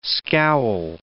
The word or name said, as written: scowl